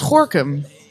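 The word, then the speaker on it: Gorinchem: Ja, dat hoor je dus uitspreken als Gorkum.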